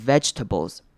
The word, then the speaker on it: vegetables: 好， vegetables 也是大家很容易念错的，不要念成 vegetables 或者是 vegetables，那个 ge 后面的 e 可以稍微省略，我们并不会把那个音节念出来，所以我们会念成 vegetables Vegetables，有没有发现念完 veg 不用母音的声音直接接到 tables 去？